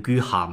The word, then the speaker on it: Queyras: 不过我讲座里面放的大吴呢，是新晋成名的一个法国大提琴家，叫 Jean Guyen Guihat，Q U E Y R A S，这法国人嘛念成 Guihat 嘛，大家可以到音乐播放器里面去找一找他。